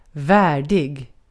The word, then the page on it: worthy: American English; [ˈwɝ.ði]